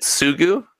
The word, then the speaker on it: Hidetsugu: We talked about this with the Hidek Suga card. Is that how you pronounce it? Hidetsugu. – Hidek Sugu.